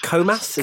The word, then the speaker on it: Comas: So looking at the test, it was at Estoril in the Ligier JS37 in January 1992, and initially used Eric, is it Comas? Is that how you say it? – Yes.